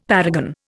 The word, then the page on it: paragon: Indian